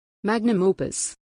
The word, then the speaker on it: magnum opus: It's his magnus opus, right? Opus? – Magnus opus. I think so. Yep. – Pronunciation. Magnum opus. Magnum opus.